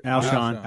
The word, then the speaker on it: Alshon: Is it Alshon? – Alshon, Alshon, Alshon Jeffries.